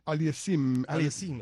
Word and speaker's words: Aliassime: España ya se había puesto en ventaja más temprano cuando Roberto Bautista Agut derrotó a Félix Auger Aliassime. No, espérate. – Aliassime, exactamente.